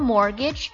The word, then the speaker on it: mortgage: Any default on your mortgage payments may mean you will lose your house. 我刚问了一个问题，我说 mortgage 这个单词怎么讲，怎么拼？注意一下 T, T 是不发音的，M-O-R, more T, mortgage. G-A-G-E, mortgage. 注意一下 M-O-R 会有一个不发音的 T-G-A-G-E, mortgage.